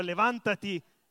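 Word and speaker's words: levántate: Ripetete con me, impariamo questa parola, levantati, levantati, levantati, e poi aiutami Pia, levantati, levantate e resplendesse.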